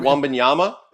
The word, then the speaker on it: Wembanyama: Victor like, Wembanyama? – Yeah.